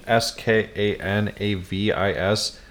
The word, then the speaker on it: Skanavis: It's spelled a little interesting: S K A N A V I S.